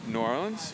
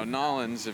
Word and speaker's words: New Orleans: What's the preferred pronunciation of things down there? – New Orleans? – Yeah. – I mean, there's, you know, Nolens. – Nolens. – It changes according to how much bourbon you've drank.